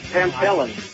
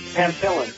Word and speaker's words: Pamphilin: Pamphilin. Pamphilin. – The pH is an F sound.